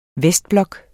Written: vestblok

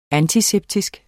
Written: antiseptisk

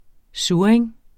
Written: SURRING

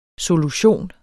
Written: solution

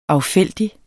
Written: affældig

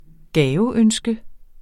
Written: gaveønske

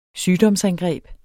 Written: sygdomsangreb

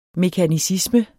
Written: mekanicisme

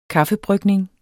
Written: kaffebrygning

